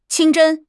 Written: 清真

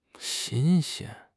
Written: xin1 xian1

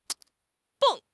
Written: 蹦

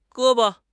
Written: ge1 bo5

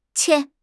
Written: qian1

